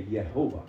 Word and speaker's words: Jehovah: He said, did you realize that in the name, in the first two letters of that name, we say J, Jehovah. They say Yehovah.